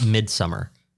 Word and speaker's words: Midsommar: I believe it's pronounced Midsommar. – Midsommar.